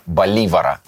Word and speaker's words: Боливар: А, я, кстати, всегда ошибочно ошибочно, как выяснилось, назвал его с ударением на последний слог фамилию, а правильно все-таки на второй. Поэтому будем мы говорить про Симона Боливара.